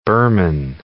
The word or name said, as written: Birman